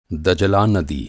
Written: नदी

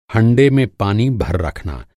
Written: हंडा

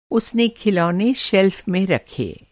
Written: शेल्फ़

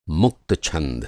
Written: मुक्त

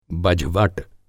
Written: बझवट

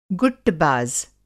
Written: गुट्टबाज़

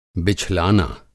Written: बिछलाना

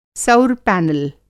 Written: सौर पैनल

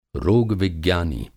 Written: रोग-विज्ञानी

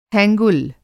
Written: हैंगुल

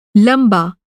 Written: लंबा

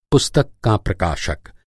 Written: प्रकाशक

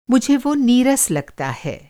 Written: नीरस

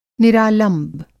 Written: निरालंब

